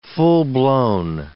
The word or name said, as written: full-blown